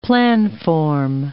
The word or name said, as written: planform